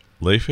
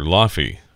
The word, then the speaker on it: Laufey: It's spelled L-A-U-F-E-Y. So when Victor requested her music, and we played it, called her Laffy. That's what it looks like. But I think it's Leife.